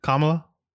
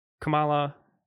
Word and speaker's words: Kamala: Is that not right? Kamala versus Kamala? – Yes. – Kamala. I didn't know that there was a difference. – You can't hear the difference? Kamala? Kamala? – No, I hear it. I didn't know that was a thing. Yeah, I didn't know that I was saying her name wrong.